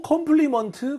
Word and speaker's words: compliment: 자, 앞에 오해 강세 찍을게요. 오해 강세 가면 항상은 아니지만 입모양 대부분 벌려진다고 했었죠. So compliment 하지 마시고요.